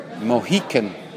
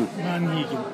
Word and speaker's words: Monhegan: In my right hand is a book called Mohican Windows. I don't know if my pronunciation... – Monhegan.